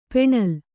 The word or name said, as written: ಪೆಣಲು